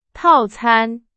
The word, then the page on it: 套餐: Chinese